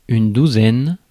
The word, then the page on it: douzaine: [yn du.zɛn]; France